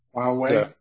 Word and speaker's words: Huawei: Huawei, the Uyghurs or.